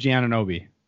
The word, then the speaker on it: Anunoby: I think it's Unawambe. Oh, OG, OG Anunoby. – Yeah, Anobi.